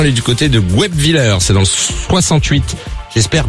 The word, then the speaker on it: Guebwiller: Guebwiller, c'est ça.